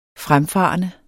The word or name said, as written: fremfarende